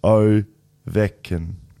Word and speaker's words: Ovechkin: Ovechkin is pronounced Ovechkin. Ovechkin. Ovechkin. It's definitely Ovechkin.